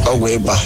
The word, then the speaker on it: Ogweba: So we call it Ogweba. – Ogweba. – That's how they pronounce it. Ogweba.